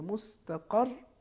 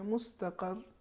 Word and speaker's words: مستقر: مثال کے طور پر مستقر، رے آخر میں ہے، اس پر تشدید ہے، تو جب ہم اس پر ٹھہریں گے تو اس طریقے سے تلفظ کریں گے مستقر، تاکہ تشدید کا پتہ چلے. اگر ہم یوں تلفظ کریں مستقر تو تشدید حذف ہو جائے گی، لہٰذا آخر میں ایک ہم شدت دیں گے تاکہ وہ تشدید، جس سے معلوم ہو کہ یہاں پر تشدید.